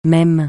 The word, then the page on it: même: francais